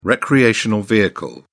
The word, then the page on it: recreational vehicle: British English